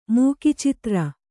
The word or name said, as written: ಮೂಕಿಚಿತ್ರ